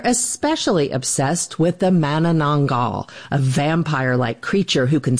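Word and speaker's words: Manananggal: And I gotta say that your pronunciation of Mananangal is quite impressive.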